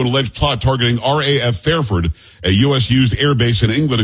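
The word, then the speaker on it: Fairford: Fafod is what I was told how I should pronounce it from K.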